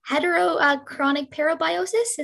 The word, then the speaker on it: heterochronic parabiosis: So I guess the scientific name is heterochronic parabiosis. Is that how you pronounce it? – That is, yes.